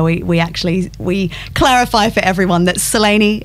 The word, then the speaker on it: Selene: Well, I don't know that I'm going to pronounce it perfectly, but it would be Selene. – Selene. – But with a bit of a UK and Oz twang, we say Selene.